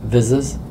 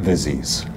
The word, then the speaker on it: vizzes: Can you clear up the pronunciation of the plural of the product of the of what Tableau creates? In other words, the visualizations that Tableau creates, you call them? – Vizzes? – Not Vizzies.